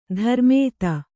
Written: ಧರ್ಮೇತ

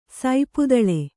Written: ಸಪ್ತಲೆ

